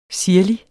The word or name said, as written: sirlig